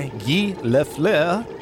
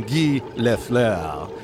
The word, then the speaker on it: Guy Lafleur: Okay, an NHL star, Guy Lafleur, if I'm saying that right. – Close enough.